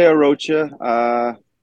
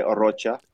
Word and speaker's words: Arocha: Most people say Arocha. My family goes by Arocha.